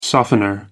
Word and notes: softener: American English

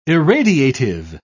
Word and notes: irradiative: British English

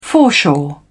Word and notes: foreshore: British English

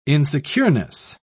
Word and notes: insecureness: British English

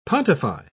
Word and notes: pontify: British English

